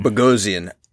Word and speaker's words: Bogosian: Bogosian.